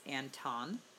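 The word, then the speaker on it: Antone: You know what's funny is I had to look up how to say his name. It's Anton Smith. Because I kept hearing it multiple ways.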